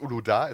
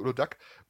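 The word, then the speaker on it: Uludağ: Also als ich die zum ersten Mal getrunken habe, ja, also es heißt Uloda, es heißt nicht Ulodak, und weil das G hat einen Strich drüber, dann spricht man das nicht.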